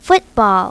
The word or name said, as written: footbal